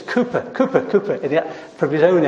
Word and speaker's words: Cowper: I know it's spelt Cowper, but I can tell you it's Cowper.